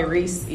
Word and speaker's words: Reese: Like Reese's cup, uh, Reese's like Reese's, Reese's. yeah, like Reese's.